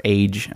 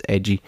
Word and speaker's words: Eiji: She falls deep into a pit and finds herself on the surface world, above ground, where she meets a boy named Eiji, or age, I think is what they, well, it's our, in our pronunciation, it's age, but it's edgy.